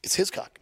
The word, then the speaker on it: Hiscock: Is that how you pronounce it? – It's Hiscock.